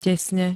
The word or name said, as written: tesne